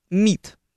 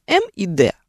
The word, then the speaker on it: МИД: Если мы имеем дело с так называемой звуковой аббревиатурой, то есть произносим слово сокращенное не по буквам, а по звукам, МИД, не М и Д, а МИД, то будем его склонять.